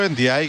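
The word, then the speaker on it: Ndiaye: Και λέω Ενδιάη γιατί στο site της Ευρωλίγκας, όπως είπε και ο Γιάννης, ο Σενεγαλέζος το προφέρει έτσι.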